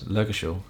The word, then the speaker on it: Lurgashall: So I know I can get like when when we did that gig, um, the how do you pronounce it? Lurgus? Lurgashall. Lurgashall, right. I, I, was, I looked at Lurgus Hall.